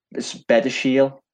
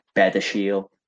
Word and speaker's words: Badiashile: So I pronounced it as Benachil. It's okay. Not spelt like that. It's spelt B A D, well, B A D I A S H I L E. But yeah, I think it's pronounced. I've heard commentary call it like Bedershield.